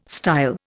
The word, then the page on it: style: Indian